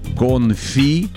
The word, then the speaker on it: конфи: Так вот, вот это все три слова заимствованы из французского языка, поэтому ударение в них падает на последний слог. То бишь «конфи», «кули», еще есть «компоте».